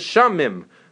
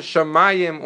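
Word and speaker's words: Shamayim (שמים): But if not, if you didn't know the Mikra Sofrim, you might pronounce it Shamim or Mitzrim, right? So the fact that we know to pronounce it Shamayim or Mitzrayim is because Mikra Sofrim.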